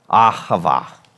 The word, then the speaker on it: ahava: And the first one is the Hebrew word ahava.